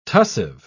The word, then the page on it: tussive: British English